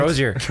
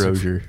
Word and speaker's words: Rozier: Rozier. – Rozier. Rozier. – Let's give it all six pronunciations. – Jake Rozier. – It's like Frazier, but it's Rozier.